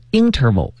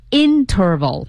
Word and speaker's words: interval: 对，当然这个词呢，我们有时也会读成 interval，就是重音在前。 – interval 啊 interval 啊。